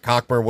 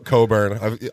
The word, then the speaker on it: Cockburn: Cockburn wouldn't have been back. Coburn. – Maybe, Correct pronunciation.